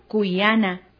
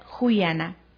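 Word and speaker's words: kuyana: Amar en la zona sierra centro del país se pronuncia cuyana. En la zona norte del país se pronuncia juyana. Nosotros aplicaremos el cuyana.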